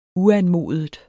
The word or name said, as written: uanmodet